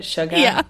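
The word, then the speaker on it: Suga: Sugar.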